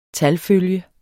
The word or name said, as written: talfølge